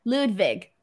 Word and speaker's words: Ludwig: Ludwig. – Yeah, but it's, yeah, with a W, so it throws me up, and I want to say, yes, Wig instead of Vig.